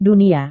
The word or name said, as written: dunia